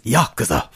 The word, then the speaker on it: Yakuza: Also for PS3 is Yakuza 4.